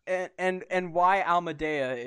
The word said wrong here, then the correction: Alameda: is the other question. I'm not sure if I said that right. – Why it's Alameda. That's Alameda.